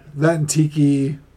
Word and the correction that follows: Taika: Taika Waititi.